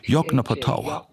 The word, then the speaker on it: Yoknapatawpha: Buchstabiert wird das Wort so: Yoknapatawpha. Ausgesprochen Yoknapatawpha.